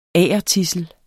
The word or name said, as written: agertidsel